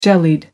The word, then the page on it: jellied: American English